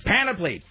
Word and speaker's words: panoply: I used to say, somebody had to correct me because I was saying, instead of panoply, I was adding an extra syllable. I was acting like panoply. – Panoply, yeah. – And so somebody, this woman, Kathy, was like, it's panoply! Stop it!